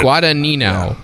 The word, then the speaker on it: Guadagnino: Well, I figured Guadagnino. Guadagnino. Guadagnino. – Guadagnino. – Guadagnino. The G is silent.